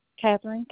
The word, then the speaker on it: Kathryn: Catherine, K-A-T-H-R-Y-N. – Mm-hmm. – Russell. – Spell that. – R-U-S-S-E-L-L.